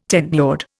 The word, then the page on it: tenured: Indian